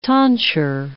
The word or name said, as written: tonsure